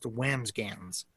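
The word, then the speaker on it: Wambsganss: I apologize to Indians fans, the second baseman, Bill Wamsgans. I apologize. So we should say though, it's spelled Wamsgans and pronounced Wamsgans.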